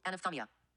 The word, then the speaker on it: anophthalmia: For those of you who want to do your own Google research, the word is spelled A-N-O-P-H-T-H-A-L-M-I-A. Surprising, we couldn't say it. – And it's pronounced like? – It's pronounced... Oh, hold on, you got me off guard. – Mm-hmm. – Oh, come on. – Here it comes. – It's pronounced like everybody knows is... – Anophthalmia. – Of course. – Yeah, that's usually how I say it, really. – Yeah. – Anophthalmia. Yes, that one.